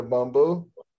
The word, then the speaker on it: Bumbu: But can I just can I just say that I just tasted the boom and I picked boom over Hennessy. – Facts. Okay, the Bumbu. – Yeah, the Bumbu. I, I pronounced it wrong.